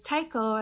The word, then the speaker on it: taiko: Am I saying it right? Taiko? – Yeah.